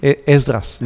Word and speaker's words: Esdras: Y libro de Ezra. Esdras, así como se pronuncia en español.